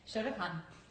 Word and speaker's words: Shotokan: Shotokan. – Did I say that right? – Exactly. That means empty hand.